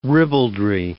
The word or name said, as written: ribaldry